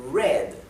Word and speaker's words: read: Sin embargo, el pasado de leer, ¿sí? Se dice read, pero se escribe igual. Así que en inglés tenemos una palabra que se escribe igual y se pronuncia diferente.